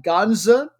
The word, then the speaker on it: Ganja: They play out of the city of Ganja, G-A-N-J-A. I know I'm mispronouncing it. It's kind of between a J sound and a softer sound, almost like a ZH.